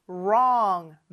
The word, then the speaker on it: wrong: L과 R 발음 중요하죠. L 발음이 혀가 윗니 뒤에 와서 딱 붙는 l, 요런 발음이고, R 발음은 혀가 어디에도 닿지 않지만 양쪽을 긴장시켜준 r, 요런 소리라고 했죠. long, wrong 많이 연습해 주세요.